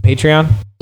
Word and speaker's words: Patreon: What I think I talked about in the last episode too was starting how do you pronounce it? Patreon? – Yes.